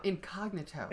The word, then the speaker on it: incognito: And then he says he wants to go to the party, but he wants to go incognito. – No, incognito. – Incognito, pardon incognito. – Me. Put the emphasis on the wrong syllable.